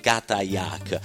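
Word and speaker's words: katajjaq: e che prende il nome e pronuncia esattamente, permettendo, di Katayak.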